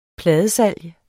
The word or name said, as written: pladesalg